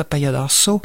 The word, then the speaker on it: Apanhador: Et Apaiadorso, ça s'écrit A-P-A-N-H-A-D-O-R, espace S-O, c'est comme un accent aigu. En fait, ça se prononce Apaiadorso.